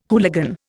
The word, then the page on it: hooligan: Indian